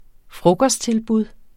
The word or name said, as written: frokosttilbud